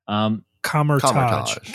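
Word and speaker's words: Kamar-Taj: When when Wanda and Strange are consulting before the big battle at the Kosmetosh. Did I say that right? Kosmetosh? I always forget. – Kamertage. – Kamertage, thank you.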